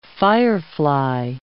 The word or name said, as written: firefly